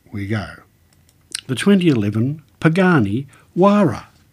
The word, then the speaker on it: Huayra: The Quechua language means the name is pronounced Huayra with a silent H.